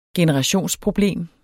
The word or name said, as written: generationsproblem